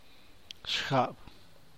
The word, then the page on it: shcan: San Mateo Macuilxochitl Zapotec